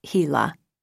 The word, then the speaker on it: HeLa: It is pronounced HeLa.